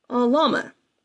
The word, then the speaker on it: Alhama: But anyway, let's go ahead and move on to entry number 12, which is, I think I pronounced it Alhama. It's actually Alhama. It's Alhama. It's spelled Alhama, but it's Alhama.